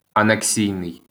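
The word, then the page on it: анексійний: Ukrainian; aneksìjnij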